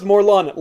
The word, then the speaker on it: Morlun: And he said, it's pronounced Morlun, like in Gun.